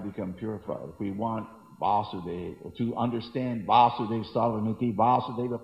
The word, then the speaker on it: Vasudeva: Vasudeva is Krishna, becomes a long A, his father is Vasudeva.